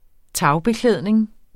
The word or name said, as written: tagbeklædning